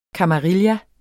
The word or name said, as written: kamarilla